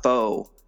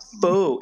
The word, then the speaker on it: pho: Apparently it's not pho. It's like some Vietnamese people have corrected me. It's not pho, it's pho. It's like, okay, whatever. It's spelled pho, like pho short or something, but no, it's pho.